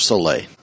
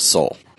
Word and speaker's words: Soule: It's not Charles Soule. It's Charles Soule. Came from the man himself.